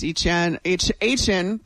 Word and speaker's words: Etienne: Travis Etienne, that's how you say it. That's how he corrected all of us.